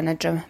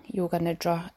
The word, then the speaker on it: nidra: I would also just like to say that in the last one, I may have been pronouncing yoga nidra incorrectly because it is yoga nidra and not yoga nidra.